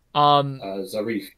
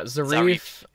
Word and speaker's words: Zarif: Zarif. – Yeah, Zarif.